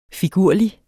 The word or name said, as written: figurlig